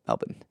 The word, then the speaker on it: Melbourne: Pronounce that right, Melbourne.